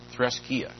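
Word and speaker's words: threskeia: And that word translated as religion is threskatia. I'll spell it for you. T-H-R-E-S. T-H-R-E-S-K-E-I-A. Threskia. I said it wrong. Threskia. That's religion, threskia.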